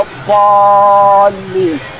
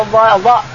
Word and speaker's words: الضالين: الضاد تطلع من بين الشفتين ولا الضال لاحظ تطلع من بين الشفتين مغلظة ولا الضالين بعض الناس ينطقها ضاد يعني يقول ولا الضاء ينطقها ولا الضالين يخرج اللسان هذا ما ينبغي القرآن أن تخرجها من بين الشفتين ولا الضالين